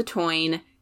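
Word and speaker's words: Táin: It looks like the tain, T-A-I-N, is how it's spelled, but it's the toine.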